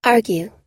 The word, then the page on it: argue: long-u